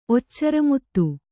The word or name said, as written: ಒಚ್ಚರಮುತ್ತು